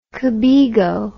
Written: cobego

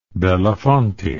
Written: belafonte

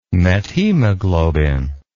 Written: methaemoglobin

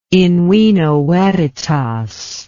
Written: in vino veritas

